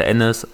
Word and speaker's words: Enes: Ja, das Ding ist halt, ich heiße Ennis, aber einige deutsche Mitbürger können das Enes. halt nicht aussprechen.